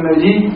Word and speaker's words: نجي: عشان نصلى على الشيء وعبد الله بن نجي بضم النون وفتح الجيم وتشديد الياء